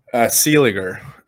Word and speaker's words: Seeliger: Seeliger. Yeah, it's always a tough one because people usually put an N before the G or, you know, whatever.